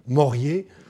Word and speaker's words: Morier: Et parmi les trois principaux Anglais qui ont parlé de la Perse, Morier, Ouseley et Fraser, ils mettent en avant le premier, alors je vais le prononcer à la française, hein, Morier,